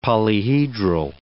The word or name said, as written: polyhedral